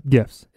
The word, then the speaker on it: GIF: GIFs. It's a hard G. Come on.